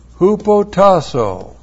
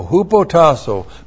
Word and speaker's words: hupotasso: Hupotasso. H-U-P-O-T-A-S-S long O. Hupotasso.